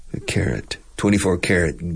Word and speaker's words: carat: It's interesting, carat. Now, carrot, lo que se come, is exact, the pronunciation is identical. Carat. Carrot. Yeah.